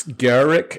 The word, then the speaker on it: Garruk: Is it Garruk or Garruk? – It's Garruk. Is how I pronounce it.